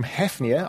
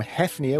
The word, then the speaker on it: hafnia: Well, it comes from hafnia. I'm going to go with the short A rather than the long A. So it comes from hafnia, which is in fact the Latin name for Copenhagen.